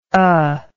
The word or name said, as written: uh